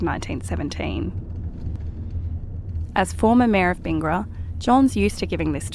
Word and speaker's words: Bingara: If you drive 7 hours northwest of Sydney, you'll come to Bingara, or Bingara, as the locals say.